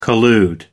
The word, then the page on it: collude: American English